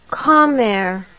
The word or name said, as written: commere